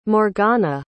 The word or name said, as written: morgana